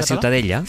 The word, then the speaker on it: Ciutadella: ¿cómo se pronuncia? – La Ciutadella. – Ciutadella. Ciutadella. Bueno, pues Ciudadela también en castellano.